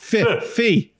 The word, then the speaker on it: phi: How do you pronounce PHI? It's not... – Fee. Like filmmaking.